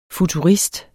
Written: futurist

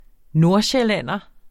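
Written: NORDSJÆLLÆNDER

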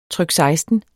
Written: trykseksten

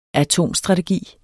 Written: atomstrategi

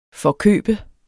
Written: forkøbe

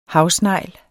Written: HAVSNEGLE

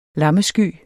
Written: LAMMESKYER

